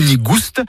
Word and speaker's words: nigouste: Nigou nigouste, avec prononciation du S originel que l'on retrouve dans le provençal goustous, goûteux, et qui a été remplacé depuis par l'accent circonflexe dans notre orthographe moderne.